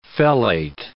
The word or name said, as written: fellate